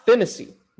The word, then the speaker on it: Phinisee: Rob Phinisee. Boom, I got it.